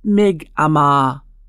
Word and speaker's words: Mi'kmaq: Mi'kmaq is pronounced Mi'kmaq. It is also spelled M-I-G-M-A-G, M-I-C-M-A-C, and M-I-K-M-A-Q.